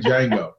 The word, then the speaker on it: Django: I was straight Django. The D is silent.